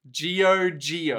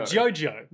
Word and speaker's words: Giogio: So, for part 5, starting on page 71, I stayed faithful to the Italian language and made the main character's name Giojo, That's G-I-O-G-I-O. – Giogio. – Giogio. – Yeah. – That's how you pronounce that.